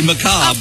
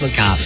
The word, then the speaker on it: macabre: It's actually macabre. – Of course it's macabre. – Yes, like, I got corn on macabre. That way you pretty good. That's the way you know you can pronounce it correctly.